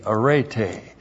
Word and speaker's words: arete: A-R-E-T, long E, arete.